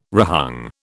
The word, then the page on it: rehung: British English